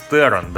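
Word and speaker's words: Theron: Шарлиз Террон, да, ее фамилия произносится именно вот так.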